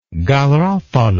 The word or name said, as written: garofalo